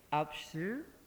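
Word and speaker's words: Alpsjö: We have with us Leif Apsu. And yes, I've been practicing that one. It's spelled A-L-P-S-J with an O with two dots. And you pronounce it Alps and the first syllable of the word sugar.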